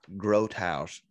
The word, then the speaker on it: Grothaus: His name is Joel Grothaus, and I've been saying his name wrong for a long time. It's Joel Grothaus, and he's a G-R-O-T, I think, H-A-U-S, maybe.